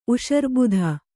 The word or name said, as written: ಉಷರ್ಬುಧ